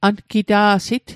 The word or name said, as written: angita'sit